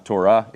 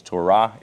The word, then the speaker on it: Torah: Everything, by the way, in Hebrew kind of ends on a, it's not Torah, it's Torah. It ends on an up note,